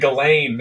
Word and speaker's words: Ghislaine: Oh man, Ghislaine, that's good. – Is that how you pronounce it? – Yeah, it's G. – So the S is silent.